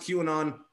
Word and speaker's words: QAnon: I know it's pronounced QAnon, but